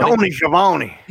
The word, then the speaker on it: Schiavone: Tony Schiavone.